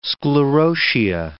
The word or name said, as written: sclerotia